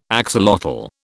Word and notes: axolotl: British English

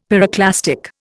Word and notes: pyroclastic: Indian

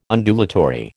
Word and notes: Undulatory: American English

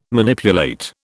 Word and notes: Manipulate: British English

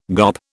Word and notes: GOP: British English

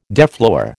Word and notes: deflower: American English

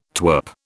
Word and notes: twirp: British English

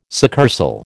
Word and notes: succursal: American English

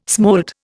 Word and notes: smolt: Indian

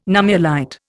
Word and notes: nummulite: Indian

